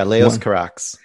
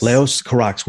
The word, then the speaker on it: Leos Carax: Is it Leos or Leos Carax? Is that how we pronounce that filmmaker's name? – Yeah, Leos Carax. Yes. – Leos Carax.